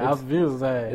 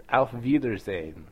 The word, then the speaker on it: Auf Wiedersehen: He just seems like he's got like a lisp over there. Auf Wiedersehen. – It's Auf Wiedersehen. – Auf Wiedersehen.